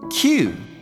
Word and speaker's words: queue: 今日は queue を紹介します。人が並ぶ列を意味するこの単語。発音は簡単ですが、スペルが queue E、トリッキーなんです。クイウイと発音しないように皆さん注意しましょうね。